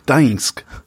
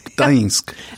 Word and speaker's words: Gdańsk: Danzig, spricht man das eigentlich? – Gdańsk. – Okay. – Gdańsk. Es ist nicht das Einfachste zum Aussprechen.